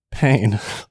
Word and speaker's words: Payne: Payne. P-A-Y-N-E.